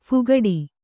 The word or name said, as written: ಫಾಕಡ